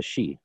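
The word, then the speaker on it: sídhe: So the word, because the gods retreated, the Tuatha Dé Danann retreated under the hills, under the she, and became equated with the hills, and therefore the she. – Yep. – Which, of course, is spelled S-I-D-H-E in modern Irish.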